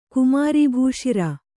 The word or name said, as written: ಕುಮಾರೀಭೂಶಿರ